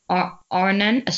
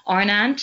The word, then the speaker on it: Arendt: Arnan, someone tried to teach me like 20 times. She was like, 20 times, repeat, repeat, repeat. Arnan, so I will spell it A-R-E-N-D-T.